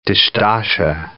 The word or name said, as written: dishdasha